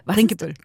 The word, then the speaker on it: Brinkebüll: Brinkebüll heißt es. – Ja. Brinkebüll. – Brinkebüll. – Erste Silbe.